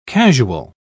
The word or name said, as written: casual